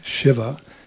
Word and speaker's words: Shiva: The very word Shiva, as we say it properly, Shiva, means auspiciousness.